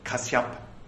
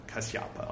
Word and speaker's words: Kasyapa: This the reason why when Kakusandha was Lord, and when Konagamana was the Lord, and when Kasyapa was Lord was the Lord, we learned at one point that Kasyapa was the proper pronunciation, uh, uh, Kasyapa, I'll say, uh, was the Lord, the Brahma life lasted long, end quote.